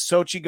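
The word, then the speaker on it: Xochitl: I believe her name is Sochi Gomez, but her name starts with an X, so I'm really bad at pronouncing words that are in English.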